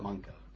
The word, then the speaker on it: Mankell: Y bueno, está leyendo la serie de novelas policiales de Henning ¿cómo se pronunciaría? Mankell. – Mankell. – Ahí está.